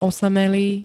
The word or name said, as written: osamelý